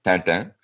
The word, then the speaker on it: Tintin: the Tintin, sorry, my wife's here, she'll kill me, I'm mispronouncing it, it's French, it's Tintin,